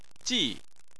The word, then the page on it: ji4: chinese